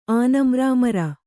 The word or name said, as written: ಆನಮ್ರಾಮರ